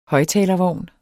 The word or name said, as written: højttalervogn